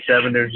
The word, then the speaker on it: 87ers: 87ers? – Yeah, the 87ers.